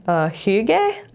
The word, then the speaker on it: hygge: There's a Danish word, hygge, I think is how you say it. H-Y-G-G-E, which refers to the coziness of home.